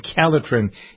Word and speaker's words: Calotren: It's spelled C-A-L-O-T-R-E-N. Calitren is the product.